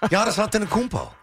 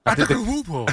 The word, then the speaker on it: Giannis Antetokounmpo: Giannis Antetokounmpo. – Antetokounmpo.